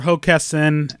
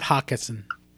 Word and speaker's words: Hockessin: Or Hockessin and not Hawkinson.